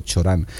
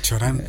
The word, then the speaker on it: Cioran: Bueno, yo una vez hablé con una romana y dijo Cioran. – Cioran, vale, me quedo con la pronunciación.